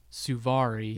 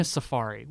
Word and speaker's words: Suvari: Jason Biggs and Mina Suvari. – Yeah. – Mina Safari. Whatever. Yeah, I just said we mispronounced names on purpose.